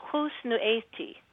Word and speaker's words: Husnu'eti: It's Husnu'eti. – Husnu'eti. Okay. – Husnu'eti. So it's the beginning. Mm-hmm. That's the one that is a killer for a lot of people.